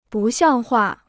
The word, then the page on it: 不像话: bù xiàng huà